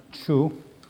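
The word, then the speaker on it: Chu (厨): So, we have the character for kitchen. It's pronounced Chu.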